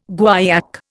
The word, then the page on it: guaiac: Indian